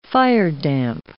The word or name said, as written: firedamp